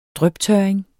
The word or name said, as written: dryptørring